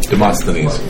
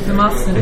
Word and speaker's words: Demosthenes: Demosthenes. – Demosthenes.